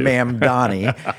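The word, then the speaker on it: Mamdani: I mispronounce it all the time. I say Mad Manny. – That's the name I wanted you to get to. – Mam Donnie.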